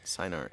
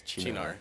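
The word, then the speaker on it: Cynar: I think one of the first or the earliest like pronunciation corrections that he did was it's not Sinar, it's Chinar. – Oh, it's Chinar.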